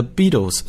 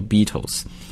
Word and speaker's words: Beatles: 我们每一次在讲新闻的时候，也都会在前面配上这样的一个电乐，就是 a cover of the Beatles song 啊。在这里有一家非常厉害的英国的乐队，我一讲大家都听到了，就是披头士，是吧？或者有的时候把它翻译成叫甲壳虫，但是那个甲壳虫呢是 Beetle 啊，叫 Beatles。虽然他们的发音是一样的，但是我们看它拼写不一样，对吧？